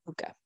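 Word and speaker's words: púca: And it's spelt P-U-C-A, but it's pronounced P-O-O-K-A. Puka.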